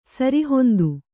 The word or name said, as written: ಸರಿಹೊಂದು